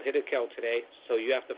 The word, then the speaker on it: Hiddekel: Now, if you look closely at the, it says, it's pronounced Hiddekel.